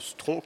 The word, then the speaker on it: Stronf: une performance de Stron qui s'écrit S-T-R-O-N-F, mais le F est muet, c'est très important pouvez venir voir.